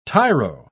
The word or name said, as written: tyro